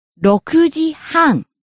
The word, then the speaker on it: 6時半: 6 o'clock is 6時. Ku is high, but half past 6 is 6時半. So 6時半 goes up at ku and stays high and drop at m.